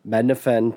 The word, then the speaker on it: Mednafen: It's by the Mednafen team. I can't believe I said that correctly.